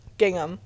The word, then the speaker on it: gingham: Luncheon and gincham. What's the name of it? – Gingham. – Gingham. Mm-hmm.